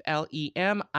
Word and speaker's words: Lemieux: It's an article on Vanity Fair by Jamila Lamol, I guess is how you pronounce it. It's Jamilah. And her last name, Lamol, it's some kind of French, I believe. Lemieux.